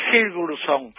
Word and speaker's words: Sigurdsson: Uno es Munson, que juega atrás y ordena bien y juega bien de alto, pero el otro es Sigurdsson. A ver si lo digo bien. – Sí, lo dijiste bien.